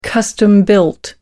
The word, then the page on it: custom built: American English